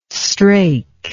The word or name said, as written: strake